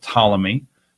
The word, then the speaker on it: Ptolemy: and in Egypt you had Ptolemy. Spelled with a silent P in front, just like pool.